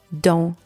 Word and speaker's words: dent: With the second word, dent, spelled D-E-N-T, the letters E and N together are also pronounced en, and the final T is silent. So both of these words, D-A-N-S and D-E-N-T, are pronounced dent.